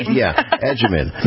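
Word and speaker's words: Agyeman: Edgeman. Phonetically figured that out like we would use in Italian, agita.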